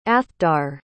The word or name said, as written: athdar